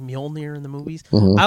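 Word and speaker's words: Mjölnir: We had to figure out our pronunciation of it. So for the movies, at least, it is Mjolnir.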